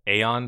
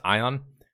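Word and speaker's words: Aion: as most people will describe, pronounced Aeon, but actually pronounced Ion.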